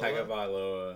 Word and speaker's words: Tagovailoa: Tagovailoa. Is it Tagovailoa? – Tagovailoa. – Yeah.